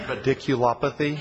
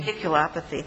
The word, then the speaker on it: radiculopathy: Radiculopathy. – Radiculopathy.